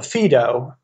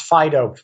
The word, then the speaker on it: Phaedo: Well, the most important early dialogue in this connection is called the Phaedo. Sometimes pronounced Phaedo, P-H-A-E-D-O.